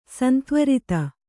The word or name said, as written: ಸಂತ್ವರಿತ